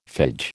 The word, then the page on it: phage: American English